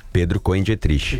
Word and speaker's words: Pedro Cohen Dietrich: Pedro Cohen Dietrich. – E como é que eu falei? – Assim, tá certo.